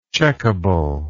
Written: checkable

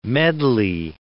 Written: medley

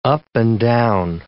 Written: up-and-down

